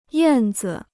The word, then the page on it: 燕子: yàn zi